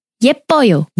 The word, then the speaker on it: 예뻐요: 예뻐요. – 예뻐요. So the emphasis is on the first syllable only. – Both really, actually.